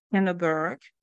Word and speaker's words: Kinneberg: First is Ms. Shauna Kinneberg.